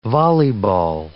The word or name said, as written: volleyball